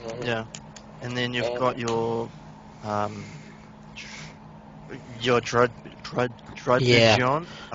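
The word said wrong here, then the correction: Druddigon: Yeah. – I was going to even try Dragon. To pronounce that. – It's just Dredigon.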